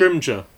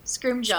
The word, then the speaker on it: Scrimgeour: Scrimgeour. – Scrimgeour.